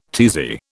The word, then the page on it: tizzy: British English